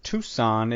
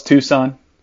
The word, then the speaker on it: Tucson: And I kind of just have one big thought, is that Tucson is not pronounced Tucson as I used to think it was. The C is silent.